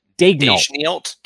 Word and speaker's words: Daigneault: And I don't know how to pronounce the Thunder's coach's last name, Mark Daigneault.